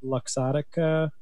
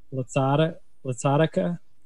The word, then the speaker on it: Luxottica: I think that was the correct way to say it, by the way, because there's an O in there. You weren't saying the O before. – Luxottica? – Yeah.